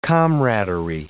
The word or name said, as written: comradery